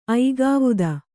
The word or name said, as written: ಐಗಾವುದ